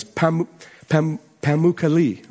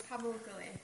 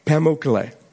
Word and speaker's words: Pamukkale: At the ancient site of Hierapolis is the Turkish town today of, I never pronounce this right, is Pamukkale, right? – Close, Pamukkale. – Pamukkale, okay.